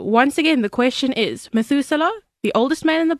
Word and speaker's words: Methuselah: So, Methuselah, the oldest man in the Bible, died at what age? Zoe, how do you say his name? Methuselah.